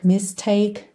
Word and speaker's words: mistake: Repara também que esses r's finais aí de heartache, mistake não são pronunciados, tá? O último som é k, k.